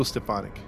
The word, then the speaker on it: Stephani: Paul, was it Stefanski? – Paul Michael Stefani. – Stefani, there you go. Stefani.